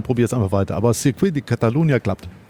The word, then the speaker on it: Circuit de Catalunya: Also du warst auf dem Circuit de Catalunya, du warst nicht in Barcelona, nur in der Stadt oder so.